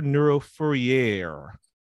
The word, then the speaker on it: Neuro Fourier: It is Dr. Neuro Fourier.